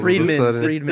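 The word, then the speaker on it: Fridman: Well, he made it very clear on that Lex Frieger. Am I saying that right? That, like, Friedman.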